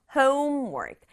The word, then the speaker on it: homework: Homework. 앞부분 강조해서 homework. Homework 이렇게 발음하죠.